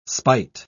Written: spite

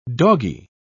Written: doggie

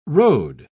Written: road

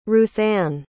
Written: ruthanne

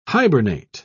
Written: hibernate

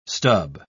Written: stub

